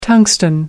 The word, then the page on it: tungsten: American English